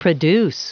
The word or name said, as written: produce